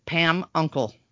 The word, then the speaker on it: Pamukkale: It's actually spelled really crazy, but I wrote next to it, Pam Uncle. – Oh, there you go. – So I would know how to say it.